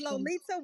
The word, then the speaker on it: Lolita: It is Lolita with a T.